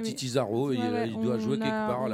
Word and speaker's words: Titi Zaro: Comme ça se prononce avec un Z, Titi comme Titi, T-I-T-I, Zaro, Z-A-R-O, Titi Zaro.